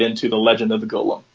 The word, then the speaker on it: Golem: It is Golem. There's like three different pronunciations they said, and there's one that's wrong. So let's go with Golem.